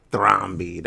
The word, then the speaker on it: Thrombey: Thrombey? Thrombey. That's how you say it.